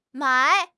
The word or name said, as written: mai3